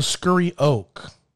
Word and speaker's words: Scurry Oak: Oh, Scurry Oak.